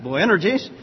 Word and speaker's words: Boanerges: And he surnamed them, he said it rhymes with energy or something like that, Boanerges,